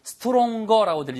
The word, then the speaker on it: stronger: 발음은요. Stronger라고 들리지 않고요. Strong처럼 들립니다. Stronger. 됐죠?